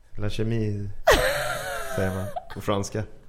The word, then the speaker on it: chemise: Jag kör en skjorta från La Chemie. Och ett par jeans från... – Uttalar man det så? – Nej, tror jag. Mm. – La Chemise. – Nej. – Skjorta på franska heter chemise.